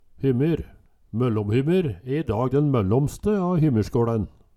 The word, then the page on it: Hymmyr, MøllomHymmyr: Numedalsmål